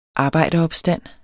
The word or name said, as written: arbejderopstand